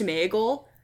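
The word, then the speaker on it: Sméagol: And what's interesting about that is that's actually how it's pronounced. – Who what? – Smeagol. If you read it, there's like an accent mark. – Oh, no. – In the book. And I think even the Peter Jackson like whole production has acknowledged that it is properly pronounced Smeagol.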